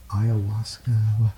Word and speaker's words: ayahuasca: I know you went over to Peru for, I try to get the pronunciation for when you did that, ayahuasca.